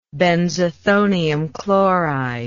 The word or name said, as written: benzethonium chloride